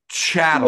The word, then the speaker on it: chattel: I mean, clearly that, you know, they're human cattle. – Why don't they all get there? – It's pronounced chattel.